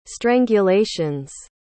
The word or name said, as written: strangulations